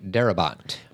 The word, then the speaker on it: Darabont: Frank Darabont. – Darabont.